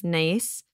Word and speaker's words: gneiss: They have schist and a little granite and this type of soil that's spelled G-N-E-I-S-S. So some people would be like, gneiss, but it's just nice.